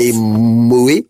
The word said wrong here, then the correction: Moët: Am I pronouncing this correctly? Moët, I think.